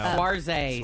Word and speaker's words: Saoirse: No, Saoirse.